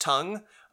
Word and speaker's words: Tung: T-U-N-G. – Yes. So pronounced tongue.